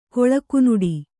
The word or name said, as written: ಕೊಳಕುನುಡಿ